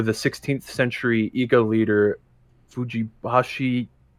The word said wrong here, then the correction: Fujibayashi: sorry if I'm butchering this, Nagato, if he's interesting enough. Fujibayashi? I'm sorry.